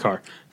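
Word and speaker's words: Kerr: I believe it's pronounced Carr, even though there's no A in it. – Yeah, it's an E. But everyone says Carr, so that's what I just said.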